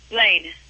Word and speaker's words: Blayne: I misheard you. Lane? – Blaine. – B-L-A-Y-N-E.